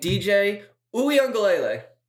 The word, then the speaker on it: Uiagalelei: Uiagalelei. It's not that hard. It's like you're saying ukulele, but it's like Uiagalelei? – Uiagalelei.